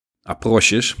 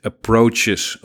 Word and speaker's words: approches: Approches, dat is wat er staat. En volgens mij spreek je het dan ook zo uit. Approches. Er staat niet approaches of approche.